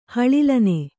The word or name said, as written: ಹಳಿಲನೆ